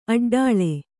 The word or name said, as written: ಅಡ್ಡೆಳೆ